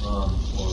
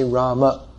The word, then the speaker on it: Rama: So to say the name properly according to Sanskrit pronunciation, we should say Rama.